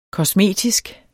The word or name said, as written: kosmetisk